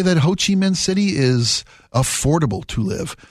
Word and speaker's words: Ho Chi Minh City: Ho Chi Minh City.